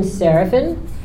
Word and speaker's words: Seraphin: Okay, hey everyone, my name is Austin Serafin. That's A-U-S-T-I-N is my first name. My last name is spelled S-E-R-A-P-H-I-N.